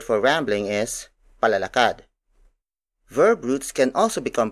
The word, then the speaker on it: palalakad: For example, palalakad, palalakad, palalakad. Palalakad is spelled as P A L A L A K A D.